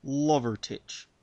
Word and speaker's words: Lovertich: Yes, it is indeed Lovertich. Spelled fairly phonetically, thankfully. – Lovertich.